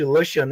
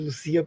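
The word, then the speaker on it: Lucía: Gosto muito também da, da Lucia Berlim, que gostava de ser chamada não de Lucia, né, mas sim de Lucia, porque ela porque ela viveu a adolescência no Chile, é ela quer é a pronúncia espanhola do nome dela, enfim.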